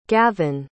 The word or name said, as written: gavin